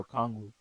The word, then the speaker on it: Okongwu: Okongwu? Is that how you say it? – Yeah. – Okongwu? – Yeah, Okongwu.